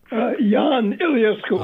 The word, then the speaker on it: Ion Iliescu: So how should I pronounce it? – Ion Iliescu. – Oh, Ion. So that's an I-O-N.